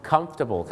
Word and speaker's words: comfortable: หลายคนก็อาจจะออกเสียงว่า comfortable ซึ่งก็มีฝรั่งบางคนนะครับออกเสียงว่า comfortable นะครับแต่จริงๆแล้วนะครับถ้าถูกต้องเลยนะฮะเขาจะออกเสียงว่า comfortable ครับ comfortable ไม่ใช่ comfortable